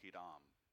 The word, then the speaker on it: Hiram: Most Americans pronounce it Hiram. – Hiram, yeah. – It would be pronounced Hiram.